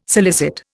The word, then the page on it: solicit: Indian